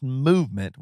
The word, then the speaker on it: MVMT: Movement Watches, MVMT, pronounced Movement, was founded on the belief that style shouldn't break the bank.